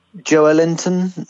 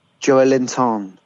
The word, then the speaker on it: Joelinton: We now pronounce it Joelinton now. – Oh, do we? – Joelinton. Joel... There's like... four syllables, I think.